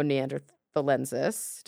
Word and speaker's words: neanderthalensis: So he is not the type specimen of Homo neanderthalensis. Did I say that right? – Yes, and you're correct.